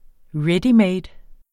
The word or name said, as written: Readymade